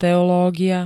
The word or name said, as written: teológia